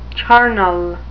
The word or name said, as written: charnel